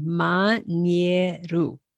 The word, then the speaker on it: Mañeru: That's a great example of all the vowels being pronounced, every letter pronounced and pronounced the same way every time. Mañeru.